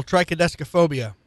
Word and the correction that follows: triskaidekaphobia: Sorry, I mispronounced it. Triskaidekaphobia.